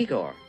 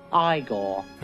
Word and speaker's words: Igor: You must be Igor. – No, it's pronounced Igor.